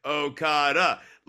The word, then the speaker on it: Okada: it's like, Okada, like even the back punch, having the emphasis on the last syllable there.